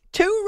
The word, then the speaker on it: Toure: Toure. I actually heard it pronounced a couple of different ways, but we'll go with the Wayne Larrivee pronunciation on Packers radio.